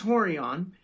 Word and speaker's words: Rorion: And I looked at, you know, Rorion, he goes, pronounced Horion.